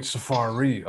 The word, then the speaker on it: Safaree: Safir. – I kept saying it wrong too. I was like, "You don't know who Safir is," and then I'm like, "Oh wait, Safaree. Oh, okay, okay."